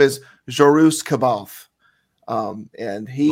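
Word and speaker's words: Jorus C'baoth: When I was reading it, I always used to call him Jorus Kaelbath. That's what. – That's the southern version. – Oh, yeah. And then I realized that I was like, no, this is not right. So I started trying to pronounce it correctly.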